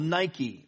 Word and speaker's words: Nike: Now, it's spelled Nike, but actually we've mispronounced that word forever since the inception of that company. N-I-K-E is actually Nike, and it's taken from the Greek goddess Nike, which is the goddess of victory,